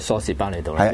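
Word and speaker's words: 梳士巴利道: 改咗叫蘇士巴利道，因為個 L 唔發音，係因為個音係譯出，即係譯錯咗。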